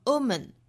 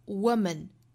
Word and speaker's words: woman: 但是这里面我们要知道它其实是 book, B-O-O-K, book 里面的那个 o 的发音，口型没有那么的聚拢，而且还要注意它其实是由辅音 w 滑动过来的，不要直接读成了 woman, 应该是 w, w, woman, woman, 所以一定有辅音再加元音，辅音要滑动到正确的元音上，